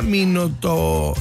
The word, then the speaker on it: Milutinovic: Se pronuncia Milutinovic.